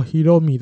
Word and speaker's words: 大海: 啊，因为大海的日文发音是 Hiromi，Hiromi。那立山监督就回答：“Hiromi。” 说到 Hiromi 的话，就是 Oka 酱。啊，小刚啊，刚大海，他说 Hiromi 就是刚大海，所以我不会叫伊藤大海 Hiromi，因为这样就跟刚大海重复了。